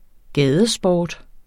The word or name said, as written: gadesport